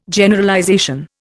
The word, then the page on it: Generalization: Indian